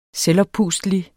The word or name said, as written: selvoppustelig